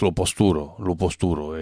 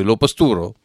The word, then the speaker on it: Lo Postouro: C'est de l'Auvergnat d'ailleurs qu'on va s'écouter avec La Pastour, mais c'est les Auvergnats donc ils prononcent Lo Posturo, et donc ça s'écrit sur le disque L-O-P-O-S-T-O-U-R-O, mais c'est Lo Posturo.